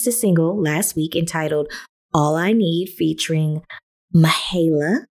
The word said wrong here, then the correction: Mahalia: or Mahalia. Do not know how to pronounce it, and I think I did it right the second time. Mahalia.